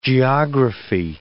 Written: geography

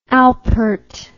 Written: Alpert

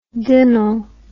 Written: dinnle

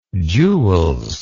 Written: jules

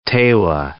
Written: Tewa

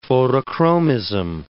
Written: photochromism

